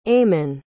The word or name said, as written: aiman